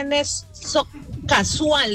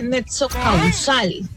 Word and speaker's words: nexo causal: No se dice nexo casual, ¿qué? Se dice nexo causal.